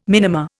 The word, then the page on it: minima: Indian